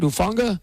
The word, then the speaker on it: Hufanga: Intercepted by Talanoa. Did I say that right? Hufanga? – Close enough.